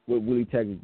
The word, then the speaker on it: Willie Taggart: It's just on what Willie Taggart could get.